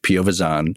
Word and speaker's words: Piovesan: So it's pronounced Piovazan. And my parents were first born in this country.